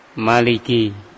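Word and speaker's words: Maliki (ملك): Maliki Yaumiddin, Al-Malik, sebagian dalam qira'ah sab'ah itu disebutkan dengan pendek, ya, dibaca pendek Maliki, ya. Ada yang membacanya dengan panjang Maliki, Maliki atau Maliki Yaumiddin.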